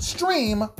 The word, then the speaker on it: Strimm: Available only on the Stream, S-T-R-I-M-M. It looks like Strim, but it's pronounced Stream Television Network.